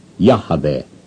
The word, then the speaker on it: Yahweh: To serve these greater interests, therefore, I shall be obliged respectfully to set aside a habit merely human and pronounce the ineffable name as Yahweh. Here are the verses. And the messenger of Yahweh said to her,